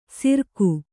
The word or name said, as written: ಸಿರ್ಕು